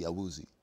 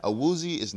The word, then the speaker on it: Awuzie: And if you were pronounced like English, you probably say Chidobe Awuzie. You know? – Yeah, Chidobe Awuzie. Yeah. That's how everybody says. – Awuzie is acceptable to me.